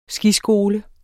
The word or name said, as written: skiskole